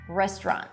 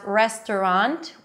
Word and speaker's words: restaurant: You can pronounce it as restaurant with three syllables, but I like the two syllable pronunciation, restaurant.